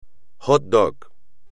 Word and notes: hot dog: espagnol

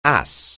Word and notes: ass: anglais

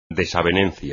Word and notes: desavenencia: espagnol

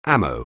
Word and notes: ammo: anglais

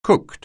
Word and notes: cooked: anglais

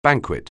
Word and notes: banquet: anglais

